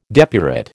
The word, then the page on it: depurate: American English